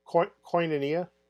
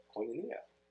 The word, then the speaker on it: Koinonia: Koinonia. – Koinonia.